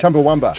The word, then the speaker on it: Chumbawamba: Chumbawamba.